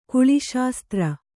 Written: ಕುಳಿಶಾಸ್ತ್ರ

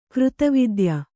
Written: ಕೃತವಿದ್ಯ

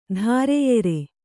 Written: ಧಾರೆಯೆರೆ

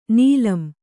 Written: ನೀಲಂ

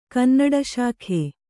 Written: ಕನ್ನಡಶಾಖೆ

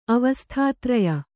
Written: ಅವಸ್ಥಾತ್ರಯ

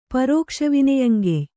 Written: ಪರೋಕ್ಷವಿನೆಯಂಗೆಯ್

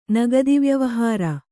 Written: ನಗದಿವ್ಯವಹಾರ

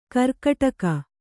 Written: ಗೀರ್ಕಡಗ

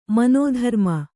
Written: ಮನಧರ್ಮ

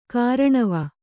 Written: ಕೂರ್ನೋವು

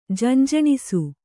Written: ಜಂಜಣಿಸು

